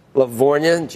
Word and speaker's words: Lavorgna: Naveen, Chief Investment Officer, Sarah Malik, SMBC Chief Economist Joe Lavorgna, G is silent, who served in the Trump administration, and Yale Professor Natasha Saron, who served in the Biden administration.